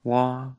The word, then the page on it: wa: Lwaitel